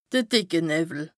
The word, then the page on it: de dìcke Nëwwel: Bas-Rhin; Strasbourg